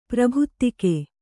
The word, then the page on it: ಪ್ರಾಭಾತಿಕ: prābhātika